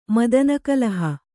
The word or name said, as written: ಮದನಕಲಹ